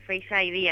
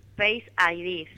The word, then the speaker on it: Face ID: El Face ID, así se dice. – ¿Cómo se dice? – Face ID.